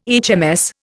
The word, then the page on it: HMS: Indian